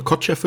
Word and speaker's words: Kotcheff: Regie hat dort Ted Kotcheff, ich schätze mal Kotcheff wird das ausgesprochen, ja, würde ich auch sagen.